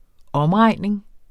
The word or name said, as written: omregning